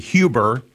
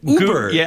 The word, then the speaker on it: Huber: A lot of people pronounce it Huber. The Germans pronounce it H-U-B-E-R as Huber. Yeah. – And I used to get in trouble because I was a smart aleck, and I would call it Uber.